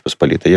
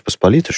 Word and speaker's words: Посполитая: Значит, Речь Посполитая, что есть республика, можно произносить двояко. – Угу. – Классическая это Речь Посполитая. – Да, и большинство поляков, как говорят, я польский не знаю, но мне это полонисты рассказывали, так и говорят.